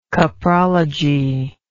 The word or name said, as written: coprology